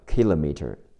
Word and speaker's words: kilometer: 远远的，far，f a r far，a r 发 r 加上 far far，名词千米公里，kilometer，美式发音是 kilometer，k i l o m e t e r kilometer kilometer，k i 读 k a k，l o 读 l a l，kilo，m e 读 m e m。